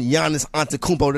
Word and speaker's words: Giannis Antetokounmpo: The aforementioned Giannis Antetokounmpo. Did I say that right? – Yeah, you're on it. You're on it, man.